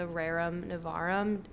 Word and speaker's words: Rerum Novarum: Yeah, so as Claire mentioned, we're going to kind of dive into the Rerum Novarum in this episode, but before we do that...